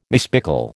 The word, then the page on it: mispickel: American English